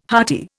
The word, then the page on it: hearty: Indian